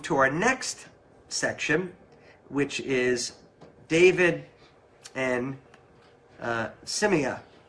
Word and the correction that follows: Shimei: How would you say that, Wilson? Give me a help there. – Which one? – Shimei? We'll go with Shimei, okay? Whatever the pronunciation is, we'll go with Shimei. – Shimei, that's right, Shimei. – Shimei? – Yeah, he's right.